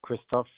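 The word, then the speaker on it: Christophe: My first name is Christoph.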